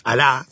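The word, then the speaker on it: alá (ἀλλά): Es el más fuerte, es alá, se escribe A-L-L-A, alia, una cosa así. – Sí. – Pero se pronuncia alá.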